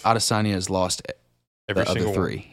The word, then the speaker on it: Adesanya: Is it Adesanya? – Yes. – Oh, yes. Is that how you pronounce it? – Yes, Adesanya and...